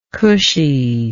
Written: cushiness

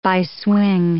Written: bi-swing

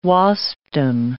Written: Waspdom

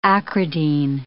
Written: acridine

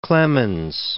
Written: Clement of Alexandria

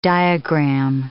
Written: diagram

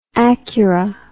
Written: acura

